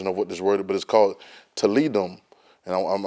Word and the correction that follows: Talmidim: I want to say it right. It's Talmidim. It's Talmidim.